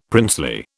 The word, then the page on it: princely: British English